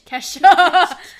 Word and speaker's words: Kesha: TikTok by um Quiche. Quiche. – Kesha. you made me say it wrong.